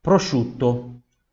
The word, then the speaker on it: prosciutto: c SCI, U-T-T-O, temos uma dópia também aqui, dópia consonante, prosciutto, prosciutto, prosciutto. Veja que esse I, esse U, eles, eles se, se fundem né, na pronúncia. Vou falar prosciutto, não, prosciutto, prosciutto. O I e o U eles se fundem na pronúncia.